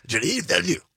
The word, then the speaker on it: Denis Villeneuve: You have to say it quickly with a lot of throat and tongue. Denis Villeneuve. Like that.